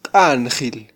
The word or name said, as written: qanjil